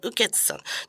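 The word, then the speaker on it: 雨穴: 雨の穴と書いて、雨つさん。 – ああ、はい。 – 読み方はね、雨つさん。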